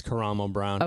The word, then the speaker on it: Karamo: I believe it's Karamo Brown. – Okay. – I could be wrong as well, but I believe that's how you pronounce that.